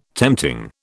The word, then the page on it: tempting: British English